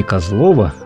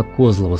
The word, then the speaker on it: Козлово: Не Козлово, а именно Козлово с ударением на первый слог.